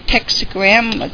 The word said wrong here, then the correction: Tetragrammaton: Pretty close. Tetragrammaton is what the word you were looking for.